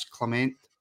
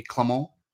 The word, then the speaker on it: Clement: And that is how it will be pronounced on the Rabble going forward. None of this Clement nonsense. It'll be Clement.